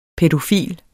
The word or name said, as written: pædofil